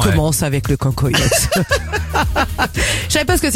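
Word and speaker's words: cancoillotte: Le cancoyote. – Oui. – Ça se prononce comme ça? – Oui, ça se prononce comme ça.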